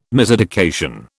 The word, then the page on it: miseducation: British English